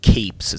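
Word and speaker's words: copse: It's pronounced capes. It's a group of small trees.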